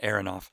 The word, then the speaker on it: Aronoff: Um, and you know, I was listening recently to Kenny Arnoff's, I say Arnoff, I think he pronounces it Aronoff, Kenny Aronoff,